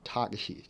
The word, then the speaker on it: Takeshi: his first name is pronounced Takeshi. Takeshi.